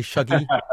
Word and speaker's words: Shuggy: I'm not going to try and do a Glaswegian accent, but if I say Shuggy, is that okay? – That's pretty good. – Okay. I'm just going to say Shuggy, but with my sort of flat London brogue or whatever it is that I've got.